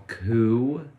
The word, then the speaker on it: Coup: Okay, so the next one I have on my list is a game called Coup. – Oh, geez. – But it's spelled C-O-U-P.